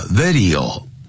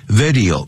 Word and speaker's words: video: serious 啊，不要念成 serious，常常听了这我们中国人常常念成 serious 啊， serious 啊， serious，第二念 serious，就像收音机叫 radio，不要念成 radio， radio， serious， serious， radio，啊，这样念 radio，像影像呢，视频叫 video， video，不要 video 啊， video， radio， serious， serious problems to humans，对人类啦， plants。